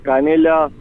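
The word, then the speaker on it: Canella: Dale, mi nombre es Marco Canela Tsuji. Canela se escribe con 2 L's y Tsuji es Tsuji.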